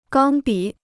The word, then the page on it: 钢笔: gāng bǐ